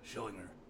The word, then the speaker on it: Schillinger: The real Vern, how do you pronounce it? – Schillinger.